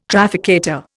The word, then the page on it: trafficator: Indian